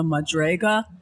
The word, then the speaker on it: madrega: Well, the levels are called, a level is called a madrega. I don't, you know, it's spelled actually in Hebrew, but it's, I guess the English transliteration is M-A-D-R-E-G-A, and the plural is madregium.